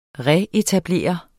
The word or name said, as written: retablere